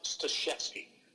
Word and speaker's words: Staszewski: And it's a Polish last name. It's actually, I know it's read that way, but it's pronounced Staszewski.